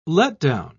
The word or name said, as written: letdown